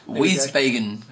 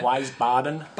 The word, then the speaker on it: Wiesbaden: Wiesbaden. – We'll go with that. – How do you say that, Wiesbaden? – Wiesbaden.